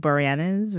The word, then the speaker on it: Boreanaz: And that show stars none other than David Boreanaz. Is that how you pronounce his name? – Yes.